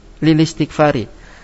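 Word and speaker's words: lilistikfari (لِلِاسْتِغْفَارِ): Nah, cara membacanya lilistikfari. Keterangan, hamzah wasal yang kedua tidak terbaca karena berada di tengah kata.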